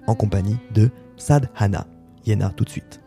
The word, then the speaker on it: Hyena: À présent de découvrir Yena, qui s'écrit H-Y-E-N-A, originaire de Bolivie, de La Paz notamment, et d'une famille quechua, mais qui vit maintenant plutôt à Bruxelles.